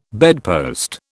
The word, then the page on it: bedpost: British English